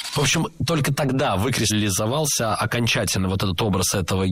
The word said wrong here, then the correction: выкристаллизовался: Было сказано «выкристаллизовался», но это неверно. Правильный вариант – «выкристаллизовался». Ударение падает на первый слог, на приставку «вы».